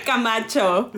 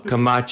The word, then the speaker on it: Camacho: Oh my goodness, Jessica, I slaughtered that, didn't I? say your okay. la- say your last name so everyone knows the right way to say it so they're not like that crazy Ty. Camacho. – Camacho.